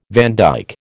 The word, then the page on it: vandyke: American English